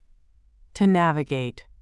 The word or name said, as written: to navigate